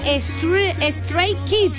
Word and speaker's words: Stray Kids: Para que me hagan el favor de poner a Stray Kids.